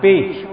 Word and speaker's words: phi: Chữ bị này là đọc theo phá âm, không đọc b mà đọc phi, cùng với chữ phi là đồng một ý nghĩa.